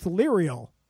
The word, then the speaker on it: Leriel: L-E-R-I-E-L. It's like Ariel, but it's Lerial.